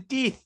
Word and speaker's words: Deeth: I think because he's Kiwi and that's how they pronounce it. Jason Deeth.